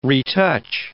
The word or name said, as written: retouch